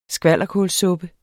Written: skvalderkålssuppe

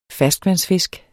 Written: ferskvandsfisk

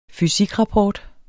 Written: fysikrapport

